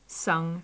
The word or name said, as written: sung